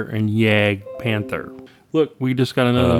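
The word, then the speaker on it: Jagdpanther: And I have been saying Jag Tiger and Yag or Jag Panther. The J is a Y, basically. – Yeah.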